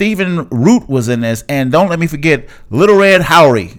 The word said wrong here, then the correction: Lil Rel: Is that how I say his name? Beat 'Em Down? – Close. It's Lil Rel.